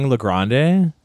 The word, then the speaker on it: LeGrande: Like, my parents say Le Grand, but, like, every time I'm at an open mic or a show, it's Le Grand. Like, Le Grand. Or it's spelt wrong because it's L-E and then it ends with an E. – Yeah. – So I've just been saying Le Grande.